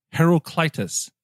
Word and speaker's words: Heraclitus: Isn't it Herculesis? Herculius. – It sounds better, but Ben reminded me in our Slack channel that it is pronounced Heraclitus. Heraclitus. Do I have that right? – Heraclitus.